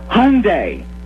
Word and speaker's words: Hyundai: This is the Hyundai Tournament of Champions. Okay, am I pronouncing the name right? – You nailed it.